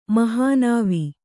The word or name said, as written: ಮಹಾನಾವಿ